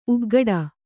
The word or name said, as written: ಉಗ್ಗಟ